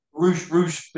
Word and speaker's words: Rush: Roosh, but it's spelt Rush.